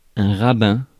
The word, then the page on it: rabbin: /ʁa.bɛ̃/